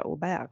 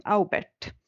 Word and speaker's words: Aubert: Jag uttalar det som på norska och inte franska Aubert utan Aubert. – Ja, för det stavas A-U-B-E-R-T.